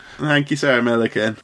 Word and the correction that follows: Millican: It's Millican, mate. Millican. Like Pelican, but Millican, that's what they said for Red Ed when they said he'd become Prime Minister.